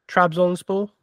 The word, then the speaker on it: Trabzonspor: or I don't know if I'm going to butcher this, but Trabzonspor. – Trabzonspor. – That, there we go.